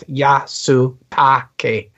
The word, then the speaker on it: Yasutake: Patty Yusatu, I've said that wrong. – Yes, Utaki. – There you go. As Alyssa Agoa. What do you guys think of this? I said at the start that I love this episode. So what do you guys think? – I learned long ago that when you speak Japanese names, you take each syllable at a time. So it's Yasutake. And hang on a second.